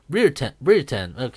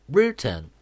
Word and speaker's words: Brereton: Brereton, que suena como que el Brere suena como el here del aquí en inglés, así. Brereton.